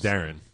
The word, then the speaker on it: Deron: It's spelled Deron, but it's pronounced Darren.